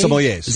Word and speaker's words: sommeliers: Sommeliers. – Is that how you say it? – Mm-hmm. Yeah.